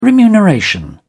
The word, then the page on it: remuneration: British English